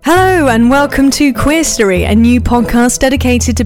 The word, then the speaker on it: QueerStory: Yeah, it's a brand new series about QueerStory is how we pronounce it, rather than QueerStory, because it is a mix of queer and history.